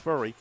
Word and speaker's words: Furry: It's pronounced Furry. Looks like Fury, but pronounced Furry.